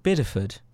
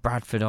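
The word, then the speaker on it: Bideford: Yeah, it was in a place called Biddeford. – Oh, Biddeford. – Biddeford. And I kept getting the name wrong before. I kept saying Bradford on blah, blah, and all this stuff.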